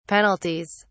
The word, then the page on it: penalties: British English